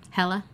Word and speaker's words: Hela: And I always pronounce her name wrong. Hela? – Hela, I think. – Hela. Yeah. H-E-L-A.